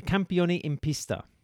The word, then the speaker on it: Campioni in Pista: Campioni in Pista. Pronunciation any good, Dave? – 10 out of 10.